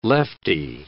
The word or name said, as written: lefty